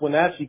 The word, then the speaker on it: Wenatchee: I kind of noticed you pronounced Wenatchee correctly, and not you didn't say Wenatchee or.